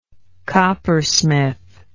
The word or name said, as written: coppersmith